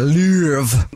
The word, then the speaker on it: Louvre: It's at the Louvre in France. – That's exactly how you pronounce it.